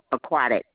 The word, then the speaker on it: Aquatic: Is I'm pronouncing this right? Aquinta Drive. – Don't feel random. Aquatic. AQUATS. Aquatic Drive.